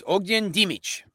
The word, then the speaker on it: Ognjen: Here's a name. I'm going to spell it for you. You try to say it. O-G-N-J-E-N. – O-G-N-J-I-N. Mm-hmm. Ogin. – Pretty close. It was Ogin Dimic. His last name was Dimic. Yeah, Ogin Dimic.